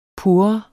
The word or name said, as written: pur